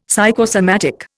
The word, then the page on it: psychosomatic: Indian